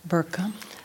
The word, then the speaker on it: burqa: You would see, now if I read it or pronounce it, the way they say in the media is burqa, right? – Burqa. – But if you pronounce it in Arabic, it's burqa, burqa.